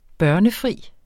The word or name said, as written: børnefri